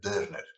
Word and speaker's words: Dörner: Dörner, pero con dos puntitos, entonces se pronuncia Dörner, según me enseña mi mujer.